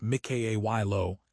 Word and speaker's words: Mykhailo: Meanwhile, in Ukraine, President Zelensky has dismissed the Minister of Digital Transformation, Mykhailo Fedorov, pronounced Mikhailo Fedorov. Fedorov was central to the integration of drone warfare and digital procurement reform.